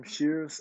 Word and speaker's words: Shears: D-E capital A-N-G-E-L-O. And last name, Shears, S-H-E-A-R-S.